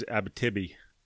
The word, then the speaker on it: Abitibi: I think the proper pronunciation on that is Abitibi.